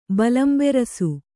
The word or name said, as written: ಬಲಂಬೆರಸು